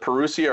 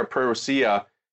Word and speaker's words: Parousia: You can either pronounce it Parousia or Parousia.